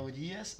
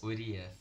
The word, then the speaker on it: Urias: Como é que fala? Você fala errado. – É, é, o que é? É Urias. – Urias.